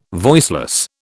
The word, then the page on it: voiceless: British English